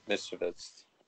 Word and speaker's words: Misiewicz: How do you how do you say that? – Misiewicz. – Mister Mis. – Mischievous. Yeah, like mischievous.